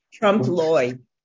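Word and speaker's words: Trompe-l'œil: Those pictures, now, you would call those pictures Trump Leol. – Thank you. I never can pronounce those things well, so I said Trump Loy is how it's pronounced.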